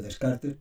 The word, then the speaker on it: Descartes: Se escribe Descartes y la mayoría de gente dice Descartes, pero bueno, en francés se pronuncia así, ¿no?